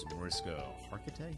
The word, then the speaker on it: Mariska Hargitay: and he really loves Mariska Hargitay. Is that how you say her name? – Yeah, she's hosting the Emmys.